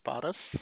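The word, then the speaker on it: Paras: This is Bottas. How may I help you? – Tell me your name again. – My name is Bottas.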